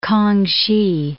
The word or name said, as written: K'ang-hsi